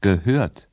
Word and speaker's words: gehört: Hören, gehört.